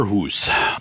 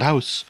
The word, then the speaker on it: Sommerhus: They really took that into account with the summer hoose. That's, by the way, how you pronounce it, hoose, not house. Or is it? Is it hoose? Oh yeah, because it's uh, it's Norse.